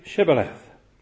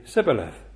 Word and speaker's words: Shibboleth: If he said no, then they would say to him, "Then say Shibboleth." And he would say Sibboleth, for he could not pronounce it right.